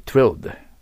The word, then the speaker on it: thrilled: 発音難しい。 thrilled R の発音しにくいから thrilled、つと言えば言いやすいです。